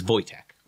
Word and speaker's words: Wojtek: As from all the videos that I watched, that when people were actually Polish and speaking this name, it was Wojtek. – The W and the V and the, mm-hmm. yeah. Okay. – So that's how it's pronounced, is Wojtek.